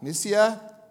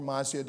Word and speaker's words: Mysia: So, passing by Mysia or Mysia, depending upon which pronunciation you want to take, they came down to Troas.